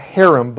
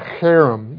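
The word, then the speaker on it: herem: That phrase, devoted to destruction, translates a Hebrew word, harem. Not harem, but harem. You've got to get the, you know, the better when you have a cold to pronounce it kind of word.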